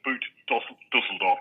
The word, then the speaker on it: Boot Düsseldorf: Düsseldorf Boat Show, or Boot Düsseldorf to use its formal title.